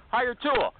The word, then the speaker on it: Hiretual: Hire Tool. Hire Tool. Hire Tool. That's how they say it, like a sneeze. Hire Tool. But they don't spell it that way.